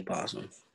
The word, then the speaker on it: opossum: I think that's the actual word, opossum. But I think people just say possum.